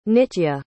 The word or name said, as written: nitya